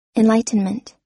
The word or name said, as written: enlightenment